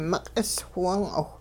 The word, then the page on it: miq'is whiwung'awh: Hupa